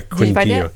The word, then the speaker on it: Quindío: Quindío in the American. I think I need to look. – Oh, yeah. Quindío.